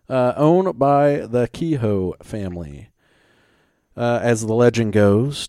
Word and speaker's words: Kehoe: We have in Savannah, Georgia, the Kehoe House. That's what I'm going to pronounce it as. K-E-H-O-E. – That's right.